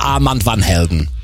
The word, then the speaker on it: Armand van Helden: Ich habe irgendwann mal Armin gefragt und er so, sag es im Englischen, dann ist es viel, viel einfacher. Armin van Buuren. Deswegen sagen wir Armand van Helden.